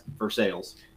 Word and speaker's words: Versailles: there is a town in Missouri that is spelled exactly like it, but pronounced Versailles.